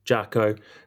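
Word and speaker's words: Jako: Is it Jacko or Jayco? – Jacko. – So it's made by Jacko.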